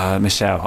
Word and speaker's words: Michel: It's Michelle. I know it's Michelle.